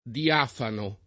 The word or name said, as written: diafano